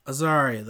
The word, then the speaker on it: Azaria: Azaria, that's how you say it.